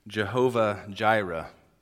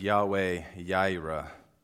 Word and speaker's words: Jehovah Jireh: And so today I've been assigned to speak about God's name, Jehovah Jireh. It's also can be pronounced as Yahweh Jireh.